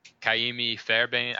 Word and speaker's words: Kaimi Fairbairn: And then Kaimi Fairbairn, I think I said that right, the kicker for the Texans.